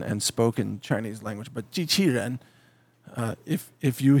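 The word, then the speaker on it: jiqiren (机器人): It's pronounced jiqiren. And it jiqiren means machine person.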